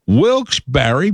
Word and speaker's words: Wilkes-Barre: Number 46 on the list, Wilkes-Barre, Pennsylvania. Or if you say it wrong, Wilkes-Barre.